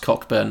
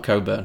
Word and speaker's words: Cockburn: Yeah, and they're very specific that it's pronounced Cockburn, not Coburn.